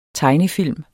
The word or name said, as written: tegnefilm